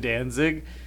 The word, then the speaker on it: Danzig: Oh, he pronounces his name Danzig.